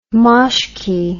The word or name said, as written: moshky